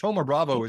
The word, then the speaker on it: Thoma Bravo: One example is Thoma Bravo, spelled like Thoma. It looks like it's Thomas minus, but Thoma Bravo